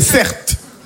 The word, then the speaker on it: Certe: Mon prénom c'est Certe. Ça s'écrit C-E-R-T-E.